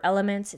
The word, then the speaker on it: LMNT: Electrolytes, I use a brand called LMNT, or Elements. It's spelled LMNT.